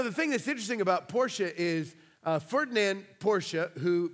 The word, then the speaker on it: Porsche: In fact, I called them Porsche until this week. I learned that that's grammatically incorrect and offensive to the Germans. It's pronounced Porsche. So, in case you're having an argument in your own house of how this is pronounced, it's technically Porsche.